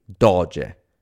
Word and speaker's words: doge: For the Venetians, the word became doge, D-O-G-E, usually pronounced in English as doge.